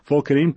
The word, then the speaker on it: Pholcodine: Pholcodine, Pholcodine. N-E. That is how it is spelt.